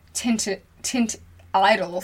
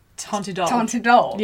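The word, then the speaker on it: Teint Idole: And I'm like, well, I watched your video last week and you were calling the Tint Idol Foundation from Lancome. – Yeah. – It's Lancome... – Tontidol. Yeah.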